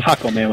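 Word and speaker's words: Jácome: Jacome, with an accent on Jacome. – The first A. Jacome, yeah.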